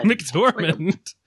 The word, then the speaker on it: McDormand: So, Frances McDormand. Did I say that right? I feel like I really fucked that up. Frances McDormand. – McDormand. – Not Dermot Mulroney. Not Dylan McDermott.